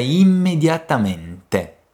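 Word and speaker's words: immediatamente: O que muda é a presença dos dois M's e a pronúncia na parte final, no ti. Por isso, a pronúncia correta é imediatamente.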